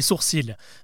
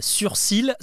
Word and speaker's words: sourcil: Mais je vous rassure, hein, on ne dit pas surcils, ça ça n'existe pas, même si ça pourrait faire sens. En fait, c'est plutôt le L qui pose problème. Tout le monde le prononce alors qu'on dit bel et bien sourcil.